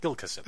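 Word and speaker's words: Gilkyson: Gilkison. That's how you say it.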